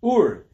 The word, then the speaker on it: ur: By the way, always mispronounced. Ur, it's an Aramaic word, like ur kastim, right? And it means fire, not or.